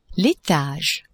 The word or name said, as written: etage